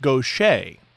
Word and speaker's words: Gauthier: Gauthier. – Yes, actually, and in that interview, we learned her name is pronounced Gauthier.